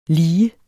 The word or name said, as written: lige